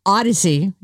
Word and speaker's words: Audacy: It's spelled A-U-D-A-C-Y. It's tricky. It autocorrects. Odyssey is the name of the app.